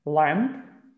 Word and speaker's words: lamb: L-A-M-B, but the B at the end is silent. So it sounds like L-A-M, lamb. That's it. But the B has to be there in writing.